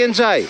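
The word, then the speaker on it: Ndiaye: Όταν ήρθε το πρώτο που τον ρώτησαν, πες μας πώς προφέρεται το όνομά σου. – Ναι. – Και είπε Εμπάι Εντζάι.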